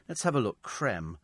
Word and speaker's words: creme: It doesn't have a thing, because just like Nestle with the acute accent, so it's still pronounced creme, as in ice cream.